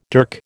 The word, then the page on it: dirk: American English